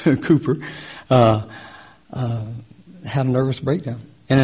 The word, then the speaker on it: Cowper: He was a pastor, and he wrote hymns, and there was a man by the name of William Cowper, and it's spelled Cowper, but pronounced Cooper, who had a similar background.